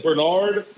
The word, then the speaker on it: Bernard: my name, is, my real name, my, my government name is Bernard Newton, and it's spelled B E R N A R D, like Bernard. – Yeah. But it's Bernard. Bernard.